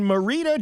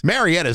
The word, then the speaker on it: Marietta: Marietta is not a hard one to pronounce. – No, it's not, and it's been talked about enough, you know, in the world that you would know that that's how you say it right.